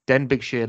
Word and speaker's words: Denbighshire: And today, I'm absolutely delighted to have Jamie Groves, the managing director of, and I'm going to pronounce this wrong, Denbighshire Leisure Limited. Um, Jamie, thank you for coming on. How are you? Yeah, good, good. Thanks for having me. Did I pronounce that correctly? – You did, spot on.